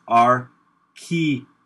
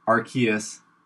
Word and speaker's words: Arceus: There, it's on Bulbapedia, but the correct pronunciation, because I'm so good at pronouncing Pokemon name, is Arceus. Arceus, uh, and I think I think that's right.